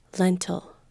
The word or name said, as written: lentil